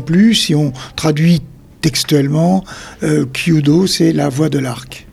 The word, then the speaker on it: Kyudo: Et donc, vous êtes spécialiste de l'arc japonais Kyudo, c'est ça, je prononce bien ? – Oui, c'est le Kyudo, c'est ça, oui.